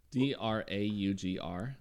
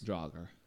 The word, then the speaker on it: Draugr: There's two different pronunciations for this, and it depends on... – Draugr? – Yes. – Draugr. – Some say Draugr, some say Draugr. So, and I listened to a lot of Swedish pronunciations of it.